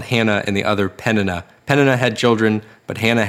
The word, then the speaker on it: Peninnah: Peninnah. And that is exactly how that's supposed to be pronounced.